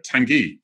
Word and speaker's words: Tangi: Just because that's the only entertainment I have in life now, but they give it, and it's Tangi, Tangai, and then they always spell it wrong anyway. But Tangi, yes, as in a hot sauce.